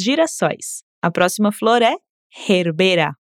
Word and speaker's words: gerbera: Essa também tem quase o mesmo nome em português, mas a gente muda a sílaba mais forte. Em espanhol é herbera e em português é gerbera.